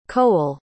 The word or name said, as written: coel